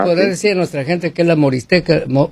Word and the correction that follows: morisqueta: morisqueta? – Morisqueta, sí, ya morisqueta, ves, ni sé pronunciarlo porque no soy de aquellos rumbos.